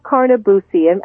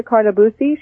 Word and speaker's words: Carnabuci: We've been speaking with Patricia Carnabusi. Am I pronouncing your last name correctly? – Yes, you are. – And that's spelled C-A-R-N-A. B-U-C-I. And remember, Patricia Carnabusi,